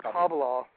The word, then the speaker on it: Kabbalah: Kabbalah, it's pronounced Kabbalah in Hebrew.